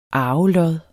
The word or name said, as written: arvelod